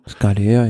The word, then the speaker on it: Scalea: Scalea spricht man so aus wahrscheinlich schon. – Scalea, ja.